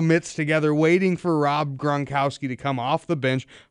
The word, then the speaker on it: Gronkowski: You know, apparently, this Gronkowski fella. Am I pronouncing that right? – I believe that's right. Rob Gronkowski.